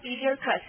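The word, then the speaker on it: elixir: 不是夸父的老婆哈、啊、那咱们看哈，弄来一种药是吧，这单词哈，Eleutherus 哈，记得哈，这单词这叫做万灵药哈，灵丹妙药哈，现在发音 Eleutherus 哈，什么意思啊？一粒克死，还不是灵烟冒药，对不对？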